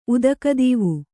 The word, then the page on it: ಉದಕದೀವು: Kannada